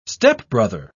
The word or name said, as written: stepbrother